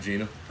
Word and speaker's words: Gina: Any dude's named Gina. – I didn't know if you thought it was Gene and you just kind of... – No, it's Gina. Spelled like vagina minus the V.